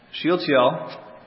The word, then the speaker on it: Shealtiel: Shealtiel, as it's pronounced in the Hebrew, your version may say Salathiel. That's the Greek rendering of it.